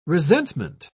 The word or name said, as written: resentment